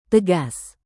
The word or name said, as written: tegas